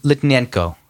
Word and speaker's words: Litvinenko: Who's Livchenko? – Is that still in the air? – No, I just started again. – Sorry, Litvinenko. Sorry, I said it wrong.